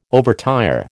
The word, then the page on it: overtire: American English